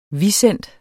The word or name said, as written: visent